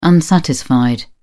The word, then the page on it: unsatisfied: British English